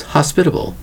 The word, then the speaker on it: hospitable: Today's word is hospitable. Hospitable. We have four syllables for the word hospitable, spelled H-O-S-P-I-T-A-B-L-E. Hospitable.